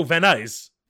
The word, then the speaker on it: Van Nuys: I'm like, oh, Van Nuys.